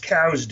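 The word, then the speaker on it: Coues: And for those people who are watching that think, Midwest whitetail, no, these are coos deer, or properly pronounced cow's deer, but they're about 85 pounds, and they are very, very attuned at staying about 450 to 500 yards away from you.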